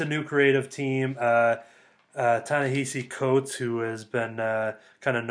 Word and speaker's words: Ta-Nehisi Coates: It is Ta-Nehisi Coates. That one I do know.